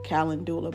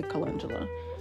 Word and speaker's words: calendula: Like if you smelled stinging nettle compared to smelling calendula, or some people would probably say it's pronounced calendula, but I say calendula, you can smell how much more nutrient dense is.